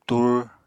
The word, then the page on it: tur: Apatani